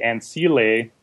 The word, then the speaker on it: Ancile: And so the last one I'm calling the Ancile, which is apparently the proper pronunciation.